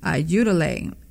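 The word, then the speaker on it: Ayúdale: It's pronounced Utilay, and ayuda means help, and lay is a pronoun referring to him, her, or you.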